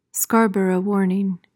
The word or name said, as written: Scarborough warning